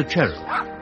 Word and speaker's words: Charwell: Michael's mother, Lady Emily, was a Cheryl, written Charwell, but pronounced Cheryl.